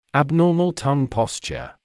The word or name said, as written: abnormal tongue posture